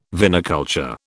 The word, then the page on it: viniculture: British English